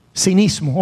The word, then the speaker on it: cinismo: Yo sé que el enemigo a veces logra desarrollar un cinicismo, ¿se dice así? Cinicismo, cynicism, cinismo, ok, thank you, gracias, necesito esa corrección.